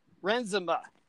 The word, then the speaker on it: Renzema: We have a special musician guest today, Chris Renzema.